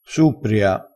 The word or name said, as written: suppria